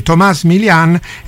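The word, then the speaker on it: Tomas Milian: E questa era My Shadow in the Dark, eseguita niente po' di meno che da, ho scoperto come va pronunciato correttamente, Thomas Millian. Per tutti ovviamente Thomas Millian, ma Thomas Millian ho scoperto essere la pronuncia corretta. Il motivo della presenza di Thomas Millian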